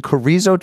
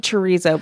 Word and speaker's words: chorizo: chorizo chipotle schmear. – It's actually chorizo, but you don't eat it, so you don't know how to pronounce it.